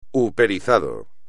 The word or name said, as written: uperizado